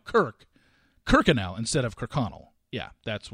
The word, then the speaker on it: Kirkconnell: and she pronounced it Kirkenell. And I'm like, that's not how you pronounce it. Not that I mean, I just the second I saw that last name, I was like, oh, that's Kirkconnell.